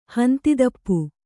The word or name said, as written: ಹಂದಿತುಪ್ಪ